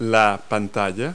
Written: screen

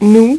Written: nous

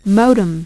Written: modem

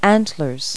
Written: antler

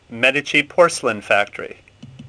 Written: Medici

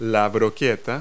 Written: skewer

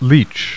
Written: leach

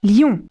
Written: lion

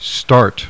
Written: start

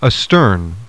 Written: ASTERN

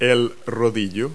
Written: roller

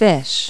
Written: fish food

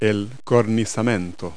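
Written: entablature

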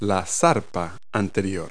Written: forepaw

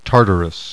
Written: Tartarus

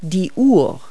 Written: clock

